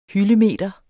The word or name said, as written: hyldemeter